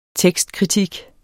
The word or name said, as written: tekstkritik